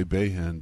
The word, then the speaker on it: Bahen: I've heard most people say Bahen, but...